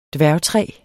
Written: dværgtræ

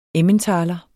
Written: emmentaler

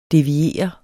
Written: DEVIER

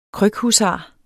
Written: krykhusar

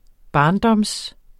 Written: barndom

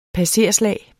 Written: passerslag

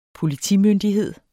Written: politimyndigheder

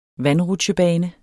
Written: VANDRUTSJEBANE

